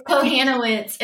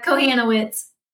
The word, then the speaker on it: Kohanowicz: It's Kohanowicz. It's a Kohanowicz, terrible last name. It's really hard to speak. Yeah, Kohanowicz.